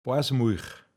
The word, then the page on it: aogramte Muich: Pinzgauer Mundart